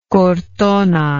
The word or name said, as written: cortona